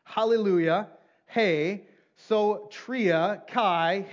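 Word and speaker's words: Hey (ἡ): And that definite article in the Greek, it's pronounced like this. Hey. Say that with me. Hey. Very simple. H-E-Y. Hey.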